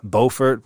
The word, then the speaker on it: Beaufort: There's a company called the Beaufort, but it's spelled Bo, B-E-A-U, Fort.